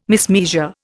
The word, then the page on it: mismeasure: Indian